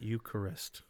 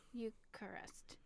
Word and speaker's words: Eucharist: But that's three syllables. I found out Eucharist. – Eucharist.